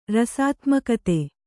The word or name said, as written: ರಸಾತ್ಮಕತೆ